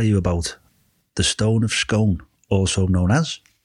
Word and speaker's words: Scone: It's written scone, but it's pronounced scone.